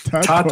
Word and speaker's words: Tatort: Como, como é, vou ver se minha pronúncia está certa aqui. Tatort, é isso? Tatort. – Tatort.